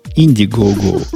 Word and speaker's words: Indiegogo: на одном из таком сервисов, на альтернативном, на Инди, Индиго, Индиго, Индиго, как оно читается? Я также Индиго. Инди, Go Go. Indiegogo.